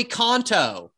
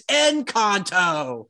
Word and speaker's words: Encanto: And Fiona, my two-year-old, goes, yeah, play Kanto. And our brother goes, it's Encanto.